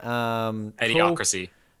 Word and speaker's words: Idiocracy: Idiocracy.